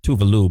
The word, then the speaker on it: Tove Lo: Technically, she goes by Tuvalu, but no one pronounces it right. – Yeah. – That's literally her name. That's really? This is not up for debate.